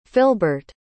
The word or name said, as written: Philbert